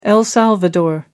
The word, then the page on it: el salvador: American English